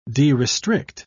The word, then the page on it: derestrict: British English